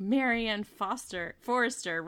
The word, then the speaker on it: Marianne Forrester: And they were like, Marianne Forrester rhymes with Martian foreigner.